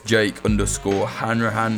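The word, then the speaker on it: Jake Hanrahan: On Twitter, we're @popularfrontco, or you can follow me, Jake_Hanrahan. That's spelled H-A-N-R-A-H-A-N.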